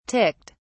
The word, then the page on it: ticked: British English